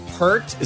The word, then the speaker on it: Peart: Neil Peart, is that how you pronounce his last name, Adam? – Yes.